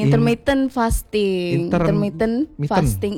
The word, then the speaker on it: intermittent fasting: Intermediate fasting, intermittent fasting. Iya. – Gitu bacanya.